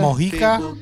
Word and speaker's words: Mojica: Yeah, but how's it spelled? – M-O-J-I-C-A. – Mocha. – What? – Mojica. – Mojica? – Mojica? – Mojica.